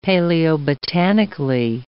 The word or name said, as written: paleobotanically